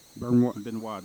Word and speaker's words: Benoit: Benoit, the T is silent.